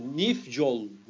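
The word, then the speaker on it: Nyfjäll: Adam Nifol. – Ni, pardon Nifjol. Nifjol. Bunlar çok garip okunuyor ya.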